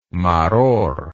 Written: maror

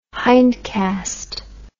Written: hindcast

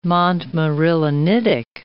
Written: montmorillonitic

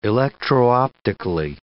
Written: electro-optically

